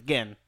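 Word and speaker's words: Gen: I thought it was spelled in Street Fighter J-I-N. – No, it's G-E-N. Gin.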